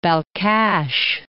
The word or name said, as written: Balkhash, Lake